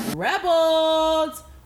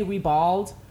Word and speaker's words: ribald: I when I saw it, I kept wanting to say rebald, but it's ribald. Okay, so just think of it like the the, the syllable break is like rib and then ald, right?